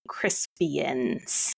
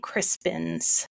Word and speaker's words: Crispin: The clue was Saintly Day of Henry V's stirring speech, and I am quite sure that I heard Arthur say, what is St. Crispian's Day? And I don't think that that is a correct pronunciation or justifiable. St. Crispin's Day is what that was called.